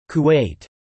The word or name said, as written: Kuwait